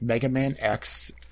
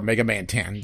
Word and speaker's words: Mega Man X: Mega Man X, or Mega Man 10, depending on how you want to pronounce that,